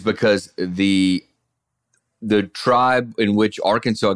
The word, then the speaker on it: Arkansas: And so they pronounced it Arkansas for so long, I guess, that it just stuck like that.